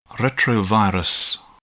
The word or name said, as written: retrovirus